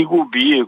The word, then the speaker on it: gubi: I gubi, penso che si dice i gubi, a pronuncia. – I gubi, con B solo.